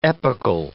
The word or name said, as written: epochal